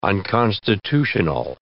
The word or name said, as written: unconstitutional